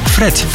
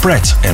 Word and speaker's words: Pratt: Vaak uitgesproken als Fred & McCain. Is het niet, het is Pratt & McCain.